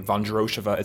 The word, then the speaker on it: Vondroušová: Vondroušová is how, more or less, how Martina Navratilova was pronouncing it on Tennis Channel during the Fed Cup tie, so I'm going to go with that. Osh, like Ocean Milos, I guess, something like that.